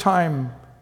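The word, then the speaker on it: time (τιμή): It's spelled like T-I-M-E in English, but time, and it means to value, to willingly look at something and go, that's amazing, that's beautiful.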